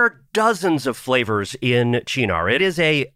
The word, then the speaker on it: Cynar: And that's C-Y-N-A-R. A lot of people think it's Cynar. No, it's actually pronounced Chinar.